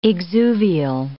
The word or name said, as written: exuvial